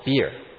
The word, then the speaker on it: beer: 那口水井呢，在圣经上的比尔或者是，that well was called beer. – Beer 就是像今天我们的啤酒这个 beer b e e r，这是发音，这个比尔。 – Same pronunciation as our beer beverage today.